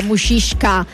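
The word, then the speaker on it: muscisca: È sicuramente un territorio anche ricco di tradizione agroalimentare, patria della mushishka. L'abbiamo già mangiata, è vero che si pronuncia così, ho detto ho imparato dall'anno scorso, è un tipico prodotto a base praticamente di carne essiccata proprio del Gargano.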